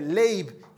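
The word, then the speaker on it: leib (לב): Cuando la Biblia habla de leib, el corazón, así, lo, así se pronuncia en hebreo, leib, el corazón, se está refiriendo a la parte más íntima del ser humano, tus pensamientos, tus emociones, tus sentimientos.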